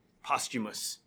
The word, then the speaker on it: posthumous: I think there is something to say about posthumous. Is that how you say it? – Mm-hmm.